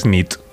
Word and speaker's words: nit: Y noche es nit. – Muy bien. Buena nit. Buena nit, es verdad. – Buena nit.